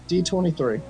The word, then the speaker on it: D23: Yeah, D23. – I don't know why it sounds wrong. Maybe it's because it's too close to C3 and that gets me confused. – No, yeah, you're right. It's D23.